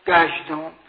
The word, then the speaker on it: کژدم: کژدم، اون هم کژ هم نمیگن به فارسی، کافش هم گاف تلفظ میکنن، گژدم دیگه.